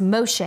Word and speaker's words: Moshe: The name Moses, translated in Hebrew, is pronounced Moshe, and it means to draw out.